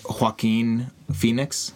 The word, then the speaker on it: Joaquin Phoenix: The score, the character piece that is Joaquin Phoenix.